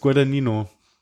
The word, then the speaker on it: Guadagnino: Guadagnino wurden wir korrigiert, dass no, das. Nee, Nino nicht. Gut. Guadagnino, dachte ich. Aber Guadagnino? Ihr werdet I'm not an Italian, so I don't know. Wir haben alle verfügbaren Möglichkeiten versucht. Wollen wir noch mal kurz Pronunciation gucken? – Nee, das ist Guadagnino.